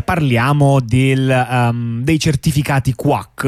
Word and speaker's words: QWAC: si scrive Q. Eh, WAC, eh, però quindi l'ovvia pronuncia quack come, eh, le, mm, come, come chi, le, papere. Come chi, chiunque lo pronuncerebbe così.